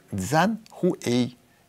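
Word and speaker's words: Цзан Хуэй (江淮): У китайцев есть такая фирма «Джак», читается как «Цзан Хуэй» и обозначает сразу две китайские реки – Янцзы и Хуайхэ.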